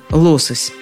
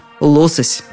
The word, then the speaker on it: лосось: При этом я так и не сумела найти ответ на вопрос – Откуда взялось ударение «лосось»? Знаете только, что как вариант оно существует. Не пугайтесь, если при вас кто-то вдруг скажет «лосось».